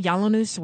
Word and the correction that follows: Yiannopoulos: Yiannopoulos, whatever.